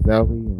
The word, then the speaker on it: Osawe: which is Vincent O'Sally.